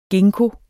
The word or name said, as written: ginkgo